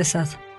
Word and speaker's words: Sade: De que es una de las características del Marqués de Sade, o de Sade, ¿no? Mucho lo pronuncia en forma alterna.